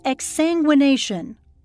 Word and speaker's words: exsanguination: It's called exsanguination. – Am I saying it correctly? – Yes.